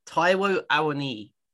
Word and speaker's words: Taiwo Awoniyi: Taiwo Awoniyi.